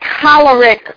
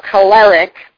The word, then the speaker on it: choleric: One is choleric or choleric, mm-hmm. – pronounced differently by people.